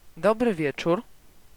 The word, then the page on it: dobry wieczór: ˈdɔbrɨ ˈvʲjɛt͡ʃur